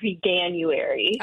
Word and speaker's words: Veganuary: And I think people pronounce it veganuary, as if you would say January, even though it's not how you say vegan.